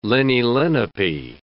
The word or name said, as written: Leni-Lenape